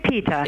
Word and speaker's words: PETA: Is it PETA or PETA? – It's PETA. – It is PETA. I've been saying it wrong my whole life.